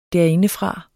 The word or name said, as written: derindefra